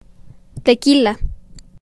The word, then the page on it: Tequila: town